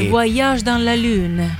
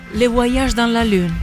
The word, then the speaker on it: Le Voyage dans la Lune: Quindi Le Voyage dans la Lune. – Lo volevo dire io con questa pronuncia, poi ho fatto un passo indietro. Puoi ridirlo? – Le Voyage dans la Lune.